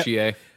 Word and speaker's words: Chie: Chie? Is that how you say her name? Yeah, Chie. Yeah.